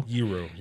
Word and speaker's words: gyro: And I was told, doing the research for George, I was told that it is pronounced gyro. – Gyro, yeah. – That rhymes with hero.